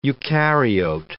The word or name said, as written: eukaryote